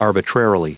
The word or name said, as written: arbitrarily